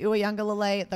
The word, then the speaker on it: Uiagalelei: And then also DJ Uiagalelei, the quarterback for Clemson. And yes, I pronounced his name right. Trust me, I've said it many, many times.